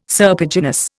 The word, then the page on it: serpiginous: Indian